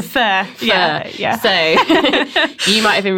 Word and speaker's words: pho: It's spelled pho. – Yes. – But so, why is it pronounced fur? – Well, so a lot of people, and I did as well, think it's pronounced faux. – Faux, yeah. And lots of other words, pronunciations as well, but it is fur, we can show you.